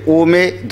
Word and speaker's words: ओम: इसके भी उच्चारण का तरीका है, जैसे में तीन मात्रा है ना, आ ऊ और मा। तो पहले आ से मुख खुलता है, आ का उच्चारण होगा, फिर ओ में ध्वनि निकलती है,